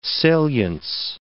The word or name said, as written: salience